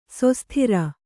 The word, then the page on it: ಶಾಸ್ತ್ರ: śastra